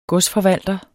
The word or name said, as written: godsforvalter